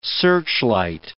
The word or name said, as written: searchlight